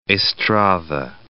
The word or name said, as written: Estrada